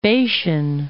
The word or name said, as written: Bashan